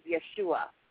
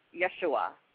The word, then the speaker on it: Yeshua: He is Yeshua. Yeshua. It's 3 syllables.